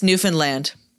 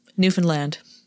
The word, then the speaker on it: Newfoundland: It's Newfoundland. – Newfoundland? – Newfoundland. – Okay. – That's my understanding since I really like Great Big Sea, the band, and they're from Newfoundland, and that's how they say it.